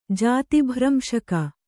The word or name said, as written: ಜಾತಿಭ್ರಂಶಕ